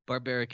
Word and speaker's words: Barbaric: Barbaric is totally fine. – I bet you had a lot of fun with that as a kid. – You know what? I'm originally from Croatia, and so the pronunciation of my last name has always been one of those things that I've just let go about 20 years ago.